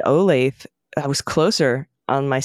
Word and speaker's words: Olathe: The pronunciation isn't Olaf. Like is not Olaf like laugh. It's rather Olathe. Olathe.